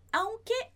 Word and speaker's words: aunque: Atenção à pronúncia. Aunque. O A é o mais forte.